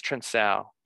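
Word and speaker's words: Trincão: I think it's pronounced like Trinchao or Trinsao. S. Trinsao. Yeah, I think it's Trinsao. Like Cancelo. The C is pronounced like an S.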